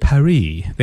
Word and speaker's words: Paris: I didn't know they pronounced it Paris. – Yes. – The French always cut off the last letters. It's like the last part of the word they just forget about.